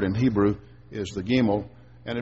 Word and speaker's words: Gimel: That little character that you see in your Bible right above verse 17 is pronounced Gimel.